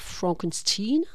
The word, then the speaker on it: Frankenstein: Oui, il est question évidemment de filiation, puisque ce docteur, donc attention à la prononciation, il faut bien dire Frankenstein,